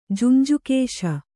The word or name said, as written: ಜುಂಜುಕೇಶ